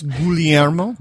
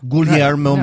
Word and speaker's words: Guglielmo: We were having a debate before on how to say his name. So I think it's Guglielmo. Guglielmo, yeah. Marconi. – You stick to the Italian pronunciation.